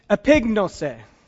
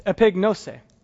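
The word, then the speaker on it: epignosis: And it's epignose. So I actually mispronounced that. It's epignose, but you got that.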